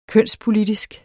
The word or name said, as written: kønspolitisk